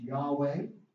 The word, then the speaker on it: Yahweh: We're told that the name really should be pronounced Yahweh.